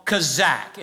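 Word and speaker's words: kazak (חזק): That word right there in the original Hebrew language is a word called kazak. It's spelled K-H-A-Z-A-Q. You may also find it spelled C-H-A-Z-A-Q. Kazak.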